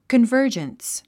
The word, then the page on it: convergence: kənvə́ː r dʒəns; コンバージャンス